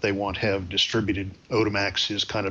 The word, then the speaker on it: Odemax: Odemax, O-D-E-M-A-X.